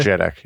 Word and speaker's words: JEDEC: If I use a utility like CPU-Z, I can see the SPD information, and I see various JEDEC. Do people pronounce it that way? – JEDEC, yeah.